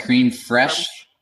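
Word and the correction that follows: crème fraîche: Creme fraiche, yeah.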